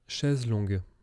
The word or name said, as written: Chaise longue